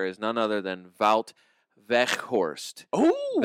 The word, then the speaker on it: Weghorst: You'll likely hear people saying Veghorst Veghorst. Okay. Which is mostly correct. It's spelled W E G. Uh huh. but the Dutch G is more like the Scottish C H.